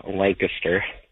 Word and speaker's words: Lancaster: It's awesome for- And I know you Californians say it Lancaster, but it's actually pronounced Lancaster.